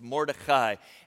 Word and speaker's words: Mordechai: And that's how you pronounce it, by the way. It's Mordechai. And the king, apparently we have it way wrong.